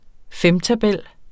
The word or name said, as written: femtabel